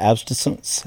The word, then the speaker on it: abstinence: But I just don't think, you know, just abstinence. How do you say that? Abstinence? Am I saying that right? – Well, abstaining from? – Abstinence. Absinthe is a drink which may lead to sex.